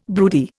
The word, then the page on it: broody: Indian